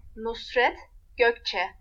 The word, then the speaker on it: Nusret Gökçe: No sabemos la historia de Nusret Gakche. A ver, vamos a ver si lo estuve pronunciando bien. Nusret Gökçe. Nusret Gökçe.